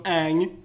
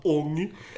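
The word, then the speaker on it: Aang: Där karaktärerna har sina namn som Ang uttalas Ong. Iro är Iro.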